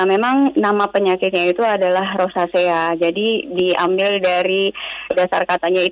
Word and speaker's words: rosacea: Itu bacanya betul rosasi uh, atau rosasea? Rosacea. Rosacea, oke. Okay.